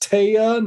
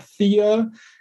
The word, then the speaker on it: Thea: And the name Thea is Scandinavian. It's properly pronounced Taya, not Thea, and it is as it is pronounced in this movie.